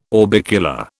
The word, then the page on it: orbicular: British English